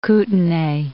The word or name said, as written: Kootenai